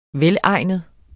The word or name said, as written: velegnet